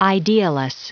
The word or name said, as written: idealess